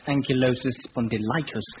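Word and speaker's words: ankylosing spondylitis: Well, the pronunciation is in fact ankylosis spondylitis, and it's a spinal complaint.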